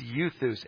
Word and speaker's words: euthus: And I know it doesn't look like that E word up there is pronounced euthus, but I looked it up. It's euthus, and it means immediately.